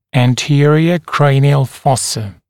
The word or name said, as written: anterior cranial fossa